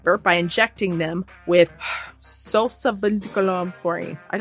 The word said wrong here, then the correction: succinylcholine: Succinylcholine. That's not an easy word to say.